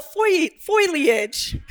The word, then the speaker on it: foliage: But if you're full of foliage, it's three syllables, y'all, that I know that part, okay?